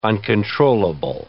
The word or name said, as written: uncontrollable